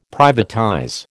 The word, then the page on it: privatize: American English